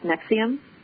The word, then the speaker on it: NXIVM: Well, it's a strange word, N-X-I-V-M, pronounced Nixium. – N-X-I-V-M.